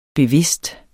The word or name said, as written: bevidst